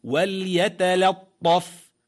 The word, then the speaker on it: وليتلطف: أي تنطق بالكلمة: وليتلطف، وليتلطف، بالهجاء لتكون أوضح. واو فتحة لام سكون، وال يا فتحة يا، وليا تا فتحة تا، وليت لام فتحة طا شدات لط طا فتحة فا سكون طف، وليتلطف.